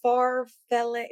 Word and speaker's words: farfalle: How do you pronounce it? Yeah, they're both. Yeah, but that's not what it's called. – Farfalle? Yeah. Okay. – Or pretty good. Acceptable.